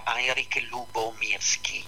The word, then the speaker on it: Heinrich Lubomirski: Allora il bambino si chiamava, io lo pronuncio alla tedesca, Heinrich Lubomirski. Se voglio, non so la pronuncia polacca come possa essere, perché non conosco il polacco. Comunque è Heinrich col K finale, sì.